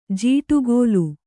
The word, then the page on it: ಜೋಡಗಲ್: jōdagal